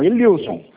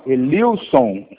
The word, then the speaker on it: Elilson: Não, Elilson. – É Ellison de Sena? – Elilson, com L.